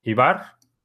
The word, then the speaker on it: Ivar: Y me dice Ivar que su nombre se pronuncia Ibar, tal como son en español.